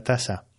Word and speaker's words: taza: Bien, ojo a la pronunciación, ¿vale? Taza, pero se escribe con Z, taza, taza.